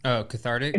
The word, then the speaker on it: cathartic: I think is the word carthitic? Is that how you say it? – Say it again. – Cathartic. It's like healing.